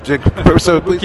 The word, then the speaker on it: Kotobukiya: It's Kotobukiya. I never say it right. – Kotobukiya, yes, that's correct.